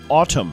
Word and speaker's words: Atum: The new Smashing Pumpkins album is called Atum, A-T-U-M, pronounced Autumn.